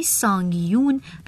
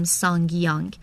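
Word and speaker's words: SsangYong: جالب اینم بدونین که تلفظ صحیح نام این کمپانی سانگیون و به معنی دو اژدهاست، نه اون چیزی که ما میگیم سانگیانگ.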